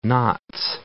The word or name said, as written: Notts